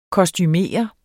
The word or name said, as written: kostumere